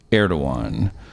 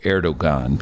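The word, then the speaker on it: Erdoğan: is a man named Erdogan. There's a silent G in the middle of that, which shouldn't be pronounced, but even if you call him Erdogan, people know who I'm talking about.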